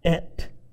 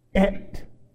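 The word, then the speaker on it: Et (את): So, when they wrote those words, Barashit, Bara, Elohim, then right after that came something that's pronounced as Et. Et.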